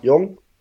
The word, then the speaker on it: Jeong: Da hat äh, Jeong, heißt der Jeong? Jong? Jong? Wie wird er ausgesprochen? – Jong. – Jong? Jong. Okay, ich habe auch K1 erfunden, von daher kann ich mir auch noch was mit Jeong einfallen lassen.